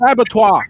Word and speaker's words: abattoir: It's pronounced abattoir. – I think if you're French, but it's one of those words it's that we co-opted.